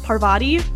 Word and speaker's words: Parvati: Another confident character is we're gonna go into right now, about 15, 16, you started to like Hermione, and how do you say this? Parvati? – Parvati. Yeah.